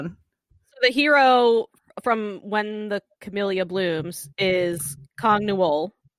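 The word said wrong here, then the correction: Kang Ha-neul: right? Yeah. Is that how you say his name? – Kang Ha-Newell. – Kang Ha-Newell. – Oh, I mixed the things.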